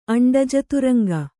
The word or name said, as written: ಅಂಡಜತುರಂಗ